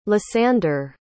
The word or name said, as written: lysander